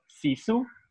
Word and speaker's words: sisu: sisu.